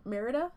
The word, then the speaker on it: Merida: Merida? – Merida. I think it's just Merida.